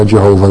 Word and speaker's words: Jehovah: We have a tetragrammaton there, J-H-V-H or Y-H-W-H, whichever you Hebrew pronunciation you prefer, Yahweh or Jehovah then.